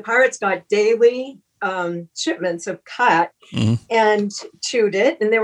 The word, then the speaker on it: khat: but they have a leaf called Cat, it's spelled K-H-A-T, and they're heavy users of this, and it's a leaf that you chew, and it's a stimulant.